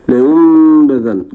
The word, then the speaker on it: لينبذن: فأول ما تتعلمه في حكم الإقلاب: لا عمل للسان فيه، لا ترفع لسانك، أنت ستنطق ميما. – نعم فضيلة الشيخ. – وأنا لم أخذ معك في قضية الخلاف هل تترك فرجة أو لا تترك، على كلا الأمرين أنت تنطق ميما، فانسى النون تماما. هذا بالنسبة لحكم لينبذن، اللي هو حكم الإقلاب.